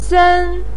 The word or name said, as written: sen1